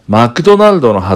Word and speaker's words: マクドナルド: で、マクドナルドの発音みんな頑張ってね。マクドナルド。みで始めると言いやすいかな。